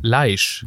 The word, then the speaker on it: Leishmanien: Leisch. Yes. Ah, also nicht wie die, nicht wie die Kröten, die Leichen. Aber mit SH, weil das anscheinend, ich glaube, weil das ein Typ namens Leichmann Leichmann erfunden hat.